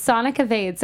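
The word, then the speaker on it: Sonika Vaid: Okay, so it's Sonic Evade. So S-O-N as in Nancy, I-K-A as in Apple, V as in Victor, A-I-D as in David.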